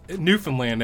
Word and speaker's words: Newfoundland: I picked Canadian from Newfoundland. – Newfoundland, actually, is how it's pronounced by the people who live there.